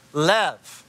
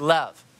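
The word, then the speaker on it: lev (לב): And it's pronounced lev. And it's a letter lamed. And then it's a letter bet, like that. And that's how it's pronounced, is lev.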